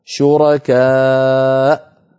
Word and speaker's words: شركاء: المثال الثالث في هذه اللوحة شركاء شرعوا في سورة الشورى، أيضا الواو هنا كرسي للهمزة والألف التي بعدها زائدة في الخط لا تنطق لا وصلا ولا وقفة، فإن وقفنا عليها نقف على همزة ساكنة هكذا شركاء.